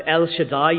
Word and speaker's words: El Shaddai: In Hebrew it's pronounced El Shaddai.